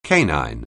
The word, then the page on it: canine: anglais